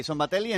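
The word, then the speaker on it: Szombathely: Los malagueños se impusieron 7-8, 8-7, 9 arriba al Falco Sombatelli, ¿lo he dicho bien? – Sombatelli, en un partido que no pudieron resolver hasta el final, a pesar de amargar con romper el partido el segundo cuarto.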